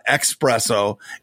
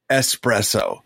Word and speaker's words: espresso: It's not espresso. It's espresso.